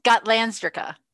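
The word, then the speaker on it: Gotlandsdricka: How do you say it? Gotlandsrika? – Are you going to let me say it? – Sorry. – It's okay. Gotlandsrika.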